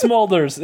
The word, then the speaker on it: Smulders: It's Smolders.